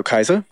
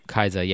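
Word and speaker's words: Kiesza: Oh, Kaiza? – Kaiza, yeah.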